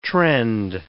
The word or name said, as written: trend